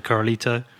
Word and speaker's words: Corralito: I've been reading recently about the Corralito.